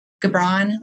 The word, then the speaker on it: Gibran: I think it's from Kabil. How do I say it? Oh, Gibran. Like anyway, Kahlil Gibran.